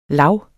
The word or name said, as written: lav